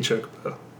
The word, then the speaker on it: Chocobo: You had Chocobos going everywhere. And... – I think you mean chocobo. But no, it's spelled like chocolate, so chocobo.